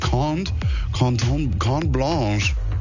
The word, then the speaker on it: Carte Blanche: Lucky Ones heißt der Track, kommt von Topic und Cont Blanche. Das sind Franzosen. Es wird Carte Blanche geschrieben, aber Cont Blanche ausgesprochen.